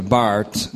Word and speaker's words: Barth: According to Barth, that's Karl Barth, and I'm going to be referring a little bit to him tonight. Karl Barth, pronounced Bart, a Swiss theologian of some fame.